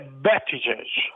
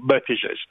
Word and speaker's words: Buttigieg: Allora, direi che avrebbe più plausibile Buttigieg.